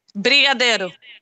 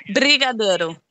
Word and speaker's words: Brigadeiro: And she says, Benjano? Am I saying that right? – No. No? I don't even know what that is. – Let me spell it for you. B. Okay. – Brigadeiro. – Yes, there we go. G-A-D-E-I-R. All right, say it again. – Brigadeiro.